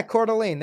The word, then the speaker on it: Coeur d'Alene: It's Coeur d'Alene? Coeur d'Alene? – Yeah, Coeur d'Alene. There you go.